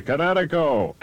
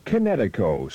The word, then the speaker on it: Kinetico: Son, you should have remembered kinetic as in kinetic energy with an O on the end. Kinetico. – Kinetico. Spell it K-I-N-E-T-I-C-O.